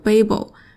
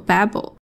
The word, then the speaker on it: Babel: 今天要讲的这本书，它的英文名，呃，英式发音叫做 Babel，美式发音叫做 Babel。